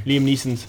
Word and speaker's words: Neeson: No, I said it wrong, didn't I? – Liam Neeson's. – Neeson. – No, Neeson's. – Neeson. No, it isn't. – Yeah, it is Liam Neeson's.